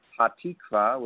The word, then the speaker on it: Hatikva: How do you pronounce it there, Rabbi? – The song I had you play to do an introduction to our show this afternoon was the Israeli national anthem, also known as Hatikva, which is translated to the hope.